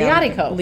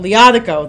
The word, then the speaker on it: Liatiko: Liatiko. – Liatiko, there Liatiko.